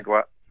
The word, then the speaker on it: Kagawa: Is that the way you pronounce that lad's name? – Yeah. – Kagawa.